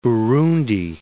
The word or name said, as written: Burundi